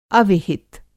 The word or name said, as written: अविहित